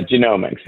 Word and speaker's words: Genomics: Is that how you say it? Genomics? Overall. – Genomics. Yeah.